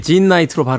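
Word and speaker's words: Jean Knight: 그런데 이제 예명을 쓰게 되면서 진나이트 예, 여기서 이제 J-E-A-N 음, 장으로 발음하느냐 진으로 발음하느냐 한테 논쟁이 좀 있긴 있었습니다만 국내에서 이제 진 나이트로 발음을 합니다.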